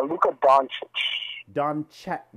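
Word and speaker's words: Luka Dončić: Moving on to number 8 is Luka Doncic from the Dallas Mavericks. Maybe I'm not even pronouncing this name right, but I think you know who I'm talking about. – Luka Doncic.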